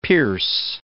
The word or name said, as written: pierce